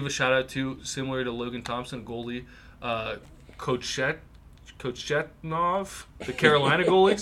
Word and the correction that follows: Kochetkov: Sorry, let me not butcher this. – Kochetkov. Yeah.